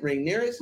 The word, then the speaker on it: Rhaenyra: Rhaenyra. So I'm like, if it wasn't for Rhaenyra, Otto Hightower would not be coming back.